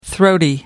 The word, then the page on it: throaty: American English